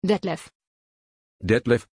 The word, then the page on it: detlef: German